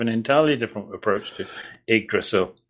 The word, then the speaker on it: Yggdrasil: As a Scandinavian, I can say that your pronunciation of Yggdrasil is entirely accurate, and if anyone doubts it, doubts it, they can hear Hugo Weaving pronounce it in much the same way in Captain America: The First Avenger, which by coincidence I saw only a few days later.